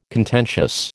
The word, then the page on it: contentious: American English